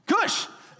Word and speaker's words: Cush: Rhymes with push. Ooh, Cush.